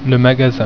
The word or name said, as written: magasin